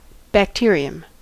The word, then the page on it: bacterium: [bækˈ̚tɪ.ɹiəm]; American English